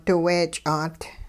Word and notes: diweech'aat: hupa

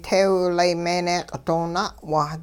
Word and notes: teewlay meeneeq' doo na'wa: Hupa

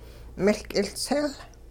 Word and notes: miL k'iLtsil: Hupa

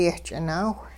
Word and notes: yehch'inaawh: hupa

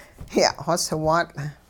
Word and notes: ya'xoswaatL': hupa

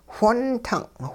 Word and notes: whontung': hupa

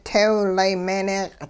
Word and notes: teewlay meeneeq': Hupa

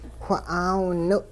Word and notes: wha'aawiniw': hupa

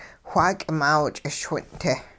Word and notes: xwaa k'imaaw ch'ischwin'-te: hupa

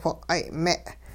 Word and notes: whiq'ay'-me': hupa

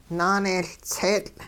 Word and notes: naaneeyLtseetL': hupa

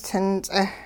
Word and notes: tin-ts'eh: Hupa

